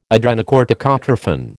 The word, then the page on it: adrenocorticotrophin: American English